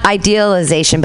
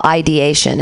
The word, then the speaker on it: ideation: It's not idealization, but it's suicidal ideation, and it's thinking about suicide because you want to have it turn out in this sort of idealized way that you...